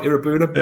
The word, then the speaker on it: Iroegbunam: I'm going to check notes, um, but for Tim Iroegbunam. – Is that right, Iroegbunam? Yeah, I've written it down phonetically now. I've put, I've put a W in there to remind me that it's Iro rather than Irogue.